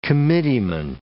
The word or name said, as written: committeeman